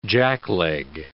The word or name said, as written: jackleg